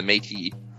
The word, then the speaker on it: Métis: Yeah, the Métis. That's how you pronounce it, by the way.